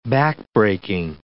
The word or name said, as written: backbreaking